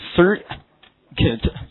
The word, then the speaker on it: circuit: 好，电路，其中这个音标是这样来的，叫做 circuit。 UI 的发音是短的，所以 circuit 这个词表示名词电路啊，记住，就是电子绕了一圈，形成一个闭路的通路叫电路，英文叫 circuit。